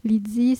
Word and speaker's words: Lydie: Lydie s'écrit l y d i edao .dao.